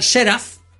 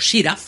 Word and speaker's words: Seraph: El submarino era el Seraph, terminado en PH, o Siraf, si lo quieres pronunciar bien.